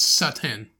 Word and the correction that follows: seitan: No, seitan. – It has a little chick over the A. – That means it's say.